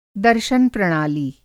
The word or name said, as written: दर्शन प्रणाली